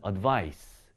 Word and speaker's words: advice: 지금 여기 앞에 있는 se로 끝나는 것은요, 발음이 advise가 되고요. 이 발음을 끝납니다. advise. 뒤에 있는 c로 끝나는 것은 advice. 이 발음으로 끝나게 되겠죠.